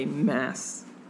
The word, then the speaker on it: Maas: Sarah J. Maas, like Massachusetts,